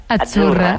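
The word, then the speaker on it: Azzurra: Es que como sí, pizza. La Z no es la Z como decimos en catalán tan sonora, no. Achurra. – Muy bonito, muy bonito. – Es como pizza.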